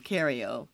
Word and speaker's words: Cariol: And so they put my name wrong as Carol instead of Cariol, but at the same time, they still gave me an appearance ticket without ID.